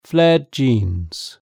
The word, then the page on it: flared jeans: British English